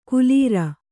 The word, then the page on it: ಕುಲೀರ: kulīra